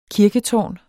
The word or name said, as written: kirketårn